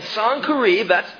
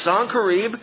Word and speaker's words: Sennacherib: After these wonderful things, this true revival in the land, Sennacherib, that is how you pronounce the name, Sennacherib, king of Assyria, came and invaded Judah.